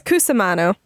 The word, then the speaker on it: Cusimano: I sometimes mess it up still, but it's Cusimano.